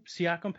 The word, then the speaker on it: Siakam: Siakam, Pascal Siakam for Toronto.